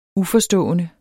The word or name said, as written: uforstående